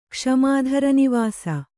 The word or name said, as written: ಕ್ಷಮಾಧರನಿವಾಸ